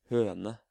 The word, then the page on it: høne: Norwegian